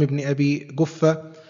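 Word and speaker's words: ابن أبي كفة: هكذا تنطق أو هكذا ينطقها أهلها، فهذه الكاف كما ينطقها المشارقة القاف، أو كما يعني كما تسمى القاف المصرية أو الجيم المصرية، فهذه هي الكاف المغربية عموما، وهي هكذا تنطق عند أهل تلك البلدة التي منها هذا الناظم العلامة سيدي أحمد بن محمد بن أحمد المكنى بابن أبي جفة المحجوبي الولاتي الشنقيطي رحمه الله تعالى.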